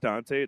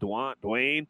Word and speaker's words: Duante: But if you watch it, I'm always start with my name's Dante. It's spelled all fucked up. It's D-U-A-N-T-E. So I get Duante, Dwayne. Like, the T silent.